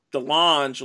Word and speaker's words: DeLonge: I've never heard DeLonge pronounced Lange or Orange. It's DeLonge, like long, but with starting with a D.